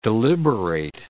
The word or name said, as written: deliberate